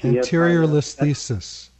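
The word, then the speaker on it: anterolisthesis: And I was at that time diagnosed with, I can't pronounce it, but it's spelled A-N-T-E-R-O-L-I-S-T-H-E-S. – Anterior listhesis.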